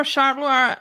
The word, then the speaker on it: Charleroi: Was it Charleroi? Might have been. Anyway, I don't know how to pronounce that. I was winging that. – He played for Charleroi and Standard Liège